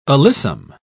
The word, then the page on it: alyssum: British English